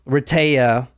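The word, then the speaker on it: Rehtaeh: So, Retea, as you probably need to say her name a few times, because it took me a while. Retea. So, it's Heather spelled backwards, but you'll see it spelled out, but that's how it is.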